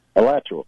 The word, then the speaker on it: Alachua: Alachua.